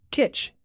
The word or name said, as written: kitsch